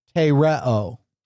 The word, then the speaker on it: tereo: It is pronounced tereo. If I were going to spell it with English letters, I would spell it T-E. R-E-O, tereo.